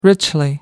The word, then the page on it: richly: American English